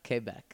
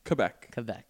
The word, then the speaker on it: Quebec: I believe it's pronounced... – Quebec. – Quebec. No. – Quebec. There we go.